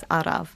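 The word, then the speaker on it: araf: You'll have arav and then slow. And lots of people who go over to Wales will say, how do you say it? It's arav.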